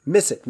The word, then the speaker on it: Missett: Judy Shepherd Missett. Missett? Did I say it right? – Yes, that's it.